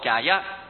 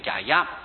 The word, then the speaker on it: 迦叶: 第一位就是沙利佛，第二位就是摩诃目犍连，两位上次已经讲了，现在是讲到第三摩诃迦叶。叶本来是沙迦沙，但是我们一般读叶读惯了，印度的本来是沙音迦沙婆，现在都是宙斯读读成迦叶，它俩是印度话。